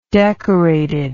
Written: decorated

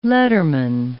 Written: letterman

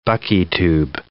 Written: buckytube

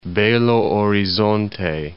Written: Belo Horizonte

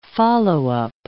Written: follow-up

